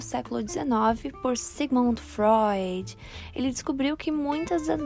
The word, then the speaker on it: Freud: Freud, que se fala Freud, se escreve Freud.